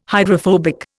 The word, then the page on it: hydrophobic: Indian